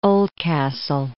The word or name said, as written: Oldcastle